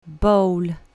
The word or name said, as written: bowl